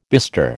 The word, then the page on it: bestir: American English